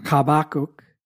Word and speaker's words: Habakkuk: Interestingly enough, Habakkuk, which is pronounced Habakkuk in Hebrew, means to embrace.